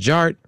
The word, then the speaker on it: DJarTT: All right, and my name is Jared, aka DJ Art, with two T's for a double dose of that ting ting. The D is silent, so it's just Jart.